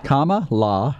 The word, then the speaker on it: Kamala: Is that right? Kamala? – Yeah, comma, then law. – Kamala Harris.